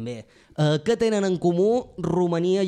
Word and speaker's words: Romania: Exacte, ho pronunciem bé, perquè com que ja sabeu que les nostres tòniques quan tornen àtones se pronuncien ús, és a dir, no hi ha cap pega. Romania, Hongria.